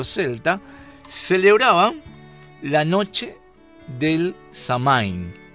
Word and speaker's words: Samhain: Y los druidas, nada más y nada menos, eran satanistas que alababan y servían al dios de la muerte, y se llama Samhain, el dios de la muerte, pero que se pronuncia Sama.